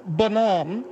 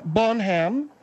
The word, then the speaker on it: Bonham: John Bonhomme, which is the proper French pronunciation, Phil. Yes, I said French. John Bonham. – John Bonham? – I didn't say Bonham.